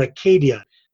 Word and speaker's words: acedia: The next word is acedia or acadia, as they pronounced it in Greek.